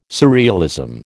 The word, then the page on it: surrealism: American English